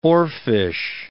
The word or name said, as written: oarfish